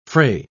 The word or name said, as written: fray